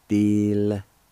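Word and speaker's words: dilitadegi: Same thing in this word. The I with the L be silent. You can pronounce the L sound with the D-I like this. Dil-ta-de-gi. Dil-ta-de-gi.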